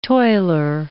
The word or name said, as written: toiler